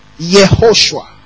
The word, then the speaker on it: Yehoshua: This J is pronounced is actually why Yehoshua, praise God, Yehoshua, praise God. So it's when the Latin uses J for it, but in the Hebrew is Y, praise God.